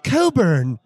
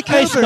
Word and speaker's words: Cockburn: The Coburn. – The Coburn. Don't pronounce cock.